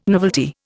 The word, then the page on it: novelty: Indian